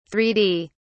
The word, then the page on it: three d: British English